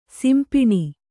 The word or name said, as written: ಸಿಂಪಣಿ